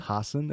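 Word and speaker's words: Hasan Minhaj: Is it pronounced Hassan? – Hassan. – I was going to say, isn't it? – It's Hassan. It's Hassan, but with two S's. – No, he got called Hassan forever, and then... He went on Ellen and was like, that's not my name. You've all been saying it wrong.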